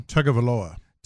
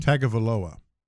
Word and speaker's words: Tagovailoa: Tagovailoa. – Tua, say it again. – Tagovailoa.